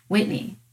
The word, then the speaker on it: Whitney: I say Whitney and they say Brittany or Courtney. And I'm like, no, Whitney, like Whitney Houston.